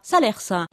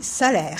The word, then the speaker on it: Salers: D'ailleurs, avis aux amateurs, on ne dit pas Salers. – On ne dit pas Salers, on dit Salers. C'est la première chose que nous avons appris sur place. On ne prononce pas le S.